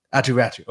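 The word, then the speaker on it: Aatu Räty: I'm sure I'm mispronouncing his name. Is it Ratto? – Atu Ratti.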